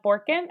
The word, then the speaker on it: Borkin: So after some technical difficulties, we're here with today's guest, Hannah Borkin.